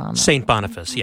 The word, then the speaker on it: St. Boniface: And how do I pronounce that? – St. Boniface. Yes, yes.